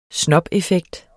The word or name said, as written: snobeffekt